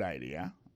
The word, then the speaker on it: idea: Alors accent vient tomber dans deuxième syllabe là, one idea. Alors moi c'est idée, vous faites fait trois syllabes vraiment I D A.